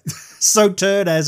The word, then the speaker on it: Sauternes: Is it Sauternes? – Sauternes. – Oh, there you go. Sauternes.